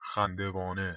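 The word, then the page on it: Khandevane: Persian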